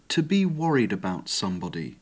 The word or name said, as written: to be worried about sb